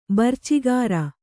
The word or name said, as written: ಬರ್ಚಿಗಾರ